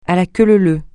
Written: à la queue leu leu

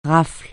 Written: rafle

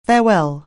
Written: farewell

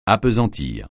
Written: appesantir

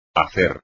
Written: hacer

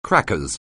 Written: crackers

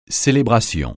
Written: célébration

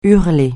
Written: hurler

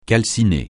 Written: calciner

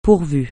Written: pourvu

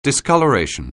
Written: discoloration

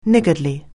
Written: niggardly

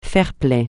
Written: fair-play

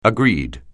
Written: agreed

